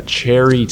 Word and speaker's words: Chairy: We're going to talk about a cherry tail, and that's chair like the thing that you sit on. So if you're searching for it, it's spelled C-H-A-I-R-Y, and it's directed by Claude Joutre and Norman McLaren from 1957.